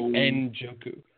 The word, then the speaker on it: Njoku: Are you going to say it like that? It's literally Njoku.